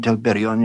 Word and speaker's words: Telperion: Laurelin and Telperion, yes, the golden song and the white silver.